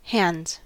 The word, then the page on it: hands: American English